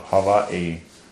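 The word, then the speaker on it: Hawaii (Hawaiʻi): Hawaii. That's how you pronounce it in Hawaiian, with a little glottal stop there, Hawaii.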